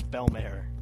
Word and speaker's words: Belmare: By the way, it's Belmare. Mare, like a horse, because I didn't know how to pronounce it.